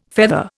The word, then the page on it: feather: Indian